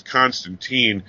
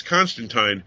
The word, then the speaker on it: Constantine: For those who don't know why we keep stressing teen and time, it goes back to the days of Alan Moore. He created the character, and then at one point he had somebody refer to him as Constantine, and he said Tyne rhymes with wine.